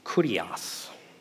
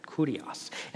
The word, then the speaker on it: kurios: The Greek word that's used here is kurios. I think I said that right, kurios.